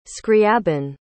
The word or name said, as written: scriabin